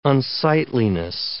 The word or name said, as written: unsightliness